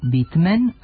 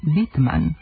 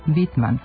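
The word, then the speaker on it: Whitman: Recimo, prezime američkog pesnika Volta, autora čuvene zbirke pesama Vlati trave, jedni izgovaraju Bitmen, a drugi Bitman. U starijim izdanjima njegovih pesama na srpskom, recimo u prevodu Tina Ujevića i Ivana V. Lalića, pisalo je Bitman, a taj oblik kao bolji preporučuje i Tvrtko Prčić u svom novom transkripcionom rečniku engleskih ličnih imena.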